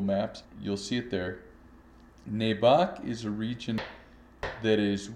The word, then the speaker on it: Nebaj: Nebac region, where we spent a couple of weeks, it's spelled Nebaj, and it's in the far north of Guatemala.